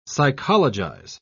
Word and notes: psychologize: British English

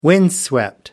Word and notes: windswept: American English

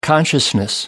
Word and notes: consciousness: American English

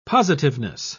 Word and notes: positiveness: British English

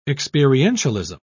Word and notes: experientialism: British English

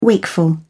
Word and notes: wakeful: British English

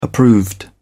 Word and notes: approved: British English

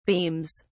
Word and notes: beams: British English

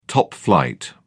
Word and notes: top-flight: British English

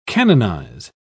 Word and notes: canonise: British English

